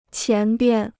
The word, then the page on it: 前边: qián bian